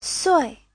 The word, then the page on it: sui: sui4